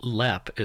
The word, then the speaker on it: Leap: So Lepp is spelled like leap, and it is.